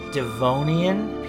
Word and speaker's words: Devonian: A geology expedition in the Amazon uncovers fossilized evidence from the Devonian period.